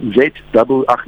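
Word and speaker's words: Zua: Then there's this guy called Zwa, Z-W, ach, no, not Z-W, it's Z-U-A. – Yeah. So, so it's, it's Zwa because his name is actually Zwa, and that's how, that, that's how it's spelled on his ID.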